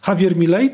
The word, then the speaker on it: Javier Milei: Javier Milei, chyba tak się wymawiać to powinno. Pan jest tutaj znawcą, więc nie wiem, czy nie popełniłem jakiegoś błędu. Javier Milei, tak to się mówi? – Tak się mówi.